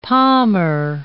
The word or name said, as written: palmer